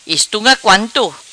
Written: Estunga Quanto

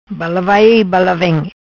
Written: Bala vai vem

